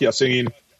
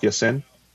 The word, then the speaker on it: Ya-Sin: They got Rock Ya-Sin last year in the second round. Rock Ya-Sin? Or Sin? I mean, my pronunciation. – I think Sin was how it was being said.